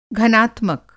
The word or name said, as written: घनात्मक